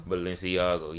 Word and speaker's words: Balenciaga: Um Balenciaga? – Balenciaga, yeah.